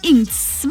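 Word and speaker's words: INXS: Sí, así se pronuncia, Inks.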